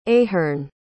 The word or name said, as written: ahern